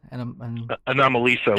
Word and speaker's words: Anomalisa: Anomalisa? – Anomalisa, like anomaly.